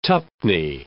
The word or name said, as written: twopenny